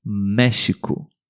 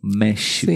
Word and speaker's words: México: O nosso querido país, que é um dos principais ouvintes do podcast, México. – México. México. – No português pronunciamos com esse som, México.